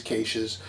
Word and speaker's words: cache: that's caches by the way to people who don't pronounce it weird like them Cash is caches, you know. It's a cache.